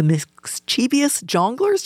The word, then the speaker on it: Mischievous Jonglers: You might remember history class for the presidents, wars, and treaties you had to memorize, but a local group called the Mischievous Jonglers, did I say that right? – That is correct.